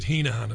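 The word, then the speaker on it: Heneghan: I think they pronounce it Hennigan. In Ireland, we pronounce it Heenahan, of course.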